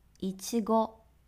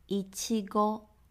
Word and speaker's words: いちご: 一，いちご，いちご。草莓，有没有很像一七五的发音呢？